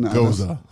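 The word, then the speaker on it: Gose: And it's spelled like rose with a G. – It is ghost. – Ghost. Yeah, that's what it should be. – Speaking of ghosts, y'all been pronounced Goza. – It's Goza.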